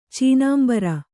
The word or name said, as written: ಚೀನಾಂಬರ